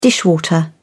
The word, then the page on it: dishwater: British English